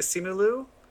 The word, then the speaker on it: Simu Liu: How do you say Simu Liu? – Simu Liu, because he's Canadian? – No, because he's ugly.